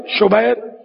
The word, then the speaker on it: شبیر: اعلیٰ حضرت نے اس کا تلفظ یہ بیان کیا، شبر، شبیر، مشبر.